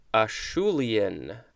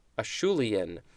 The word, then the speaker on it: Acheulean: Acheulean. Acheulean.